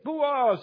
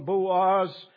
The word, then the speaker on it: Boaz: Chapter 2, verse 1, Boaz falls into two parts, two syllables.